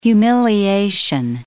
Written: humiliation

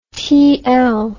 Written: tl

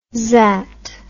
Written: zzzat

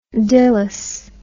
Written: Dilys

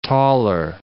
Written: Toller

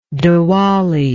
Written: dewali

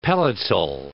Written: pelletal

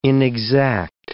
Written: inexact

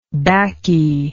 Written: baccy

